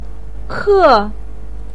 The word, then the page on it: ke4: pinyin